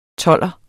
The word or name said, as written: tolver